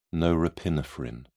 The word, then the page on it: norepinephrine: British English